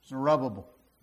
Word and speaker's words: Zerubbabel: So I've pronounced it two different ways. Zerubbabel, Zerubbabel. Which one is it? Zerubbabel. That's easy enough.